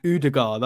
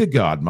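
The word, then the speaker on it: Ødegaard: That's Haaland and Udegaard, that's how you're supposed to say it. My cousin Udegaard. Lives in Norway. – It's Udegaard, man.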